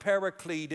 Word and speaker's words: paraclete: Notice the capital H, it's because it's a translation of a Greek word that's pronounced paraclete.